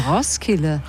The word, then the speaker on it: Roskilde: Ah, so spricht man es aus, Roskilde. Man spricht das D gar nicht so richtig.